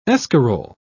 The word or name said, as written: escarole